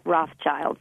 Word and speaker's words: Rothschild: And Byron, there is a sneaky silent S in that Rothschild.